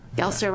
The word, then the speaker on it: Gelser: Gelser, rhymes with Alka-Seltzer.